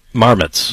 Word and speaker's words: marmots: Even know how to pronounce that. Is that the... – Marmots. – Marmots? – Yep. – M-A-R-M-O-T-S.